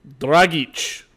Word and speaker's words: Dragic: it's almost like they would benefit from keeping Dragic around. Am I saying that right again? I mess up his name every single time. – Dragic. Like, Ivan, like Ivan Drago.